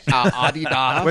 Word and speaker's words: Adidas: Adidas. – Which is the actual German pronunciation.